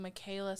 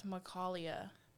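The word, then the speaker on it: Micalea: My other one was, and we were pronouncing her name wrong. We were saying Michaela Smelter. It's Macalia.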